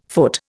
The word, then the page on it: foot: Indian